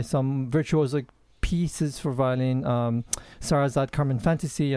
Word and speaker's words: Sarasate: and I understand the Sarasate.